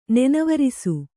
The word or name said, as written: ನನೆವೆರಸು